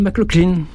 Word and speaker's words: McLaughlin: John McLaughlin, dans les bronches, pour rectifier ma prononciation de son joli nom. Ça se prononce comme ça s'écrit, effectivement.